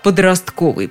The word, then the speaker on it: подростковый: Словарь ударений Агеенко и Зарвы наконец-то разрешил нам ставить ударение на третье «о» в слове «подростковый». Подростковая одежда, подростковый доктор, подростковый жаргон.